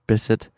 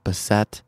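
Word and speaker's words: Bisset: But for today, I have a feeling you know who Miss Bissett is, and it is pronounced Bissett, not Bissett, and she made clear of that when we sat down earlier this week.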